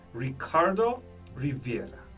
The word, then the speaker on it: Ricardo Rivera: Today, I have a special guest in the studio, Ricardo Rivera.